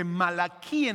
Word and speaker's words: Malaquí: y quiero decirte también que malaquí en hebreo, que es el nombre como se pronuncia, es la terminación malaquí y significa mi mensajero.